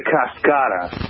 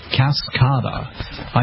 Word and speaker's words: Cascada: It's the Cascada. – Cascada.